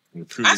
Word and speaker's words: repudiate: Did I say it right? Repudiate? – Yeah. – Bullshit people. – Repudiate.